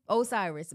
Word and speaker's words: Osiris: So in terms of Sukihana, so basically she was violated by YK Cyrus. Is that how you say his name, bro? Osiris. YK Osiris.